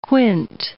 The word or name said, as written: quint